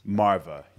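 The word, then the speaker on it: Maarva: It's Marva, right? – Marva.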